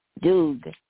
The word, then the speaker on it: Doeg: And one of his people, an Edomite named Doeg, I believe is how it's pronounced. Doeg. I would say Doeg is how I would pronounce it.